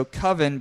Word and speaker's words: Coven: Um, so Coven is spelled C O V E N, which we know as Coven. – Mm-hmm. Um, so Coven, but he pronounced it Coven.